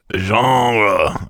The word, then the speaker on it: genre: Genre, I believe is how it's pronounced.